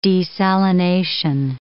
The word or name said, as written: desalination